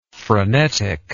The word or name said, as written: freneticness